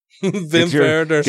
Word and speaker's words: Wim Wenders: Werner Schroeter, and Wim Wenders. They're spelled with W's, but I pronounced it like a German. – Werner. – Wim Wenders.